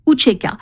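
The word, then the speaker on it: UÇK: L'armée de libération du Kosovo, qui s'écrit U-C-K, avec un signe diacritique en dessous du C, se prononçait Ucheka.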